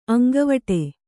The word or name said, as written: ಅಂಗವಡಿ